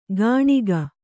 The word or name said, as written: ಕಣಿಕ